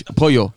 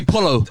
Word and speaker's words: pollo: How do you say chicken in Spanish? Pollo. You're going to say polo.